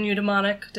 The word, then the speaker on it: eudaimonic: You distinguish between hedonic and eudaimonic.